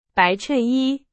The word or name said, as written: 衬衣